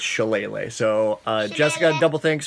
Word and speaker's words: shillelagh: Oh, Jessica also told us that um, the druid spell that you like to use so much um, is pronounced uh, shillelagh. Shillelagh. Like a ukulele.